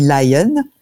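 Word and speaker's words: Lyon: Ça s'écrit Lion comme la ville, mais en anglais, ça dit Lion.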